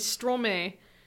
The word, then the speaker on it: Stromae: he's Stromae, and that's because he flipped the syllables of Maestro.